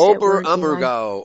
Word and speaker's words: Oberammergau: How do you pronounce it? – Oberammergau. Oberammergau Passion Play.